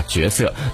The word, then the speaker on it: 角色: 嗯，其实不只是台湾腔会这样读，我觉得现在很多年轻人可能有一些人分不清这个多音字的读音，也会把角色读成角色，一不小心就说成了台湾腔了。